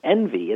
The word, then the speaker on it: NVY: Envy. It's spelled NVY, but it's Be the Envy of Your Friends.